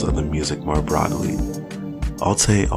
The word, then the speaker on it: alté: And the accent in alté, you know, they got the accent aigu. You see, I'm taking French. You see, I know what I'm talking about. The accent aigu. So when pronounced, it's pronounced like alté, which is a pidgin abbreviation for authentic.